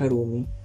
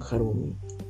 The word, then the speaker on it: Harumi: Pero todo el mundo le dice Harumi Y yo así de, o oh, sabes que si la H va al principio acompañada de una A, entonces la H sí se pronuncia como una J.